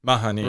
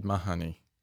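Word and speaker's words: Mahoney: You had Reid Marnie out. Or what, how are we meant to pronounce his name? Mahani. – Reid Marnie. – Reid Mahani. Yeah. As Ken O'Carawong said, Reid Mahani.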